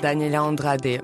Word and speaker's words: Daniela Andrade: Et on démarre et eh bien, cette émission justement avec une première illustration euh, musicale, du Daniela Andrade. C'est comme ça qu'on prononce Exactement, Daniela Andrade, voilà, qui a sorti euh, un EP euh, plutôt RB.